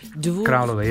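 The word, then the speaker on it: Dvůr Králové: Dvur, Kralove, yeah. – Dvur Kralove. Oh, how do you say the V? – Dvur. Dvur Kralove.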